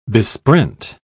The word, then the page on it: besprent: British English